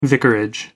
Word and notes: vicarage: American English

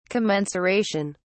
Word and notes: commensuration: British English

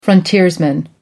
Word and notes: frontiersman: American English

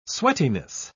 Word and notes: sweatiness: British English

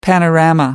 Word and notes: panorama: American English

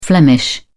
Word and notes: flemish: British English

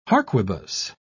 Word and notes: harquebus: British English

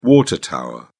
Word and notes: water tower: British English

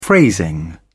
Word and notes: phrasing: British English

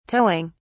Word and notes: towing: British English

